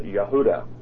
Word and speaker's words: Yehuda: The word Jude, the name Jude, is the English form of Judas, or if you were speaking Aramaic or Hebrew, you would pronounce it Yehuda.